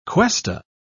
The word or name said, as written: cuesta